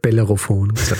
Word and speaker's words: Bellerophon: So wie ich es bei der Folge über St. Helena gemacht habe, wo ich Bellophon statt Bellerophon abwechseln.